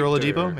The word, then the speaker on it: Oladipo: I don't remember if it was a blurb on ESPN or what, but Oladipo, is that how you say his name? Yeah, Victor Oladipo, yeah, out of Indiana.